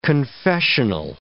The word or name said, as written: confessional